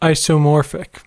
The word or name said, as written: isomorphic